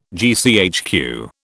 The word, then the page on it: GCHQ: British English